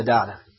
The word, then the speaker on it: data: And that means that the gospel, the very heart of our Christian religion, is basic to good data, to good understanding of the data, or data, or however you want to pronounce it. You know, I'm pronouncing it the Hebrew way.